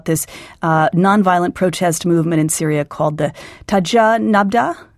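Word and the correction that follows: Tajammu' Nabd: Is that, did I say that correctly? – Tajemmu' Nabd. Tajemmu' Nabd is a group founded by an Alawite from Homs who actually goes out and tries to stop sectarianism within the protests.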